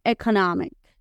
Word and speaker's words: economic: Económico economic. Economic. ¿Ven cómo se escribe parecido, pero se pronuncia diferente? Porque vamos a cerrar más las vocales. Economic. En lugar de economic, cerramos más la boquita con las vocales.